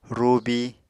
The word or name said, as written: rubi